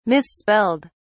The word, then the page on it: misspelled: British English